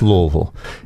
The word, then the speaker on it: Louisville: There's no S in Louisville. – No, there is not. It's Louisville.